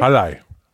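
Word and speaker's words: Halley: Wall Wie spricht man denn aus? Wallei? – Hallei. Hallei. Genau.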